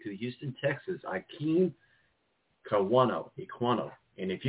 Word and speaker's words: Ekwonu: It goes by Icky, you know, Icky Ekwonu is how you say it.